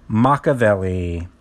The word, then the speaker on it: Makaveli: Machiavelli. That is spelled M-A-K-A-V-E-L-I.